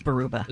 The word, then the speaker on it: Baraboo: But it's like Baruba. – It is Baruba.